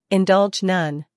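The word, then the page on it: indulge: uk /ɪnˈdʌldʒ/; en-us /ɪnˈdʌldʒ/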